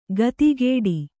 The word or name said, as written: ಖಾತಿಗೆಡು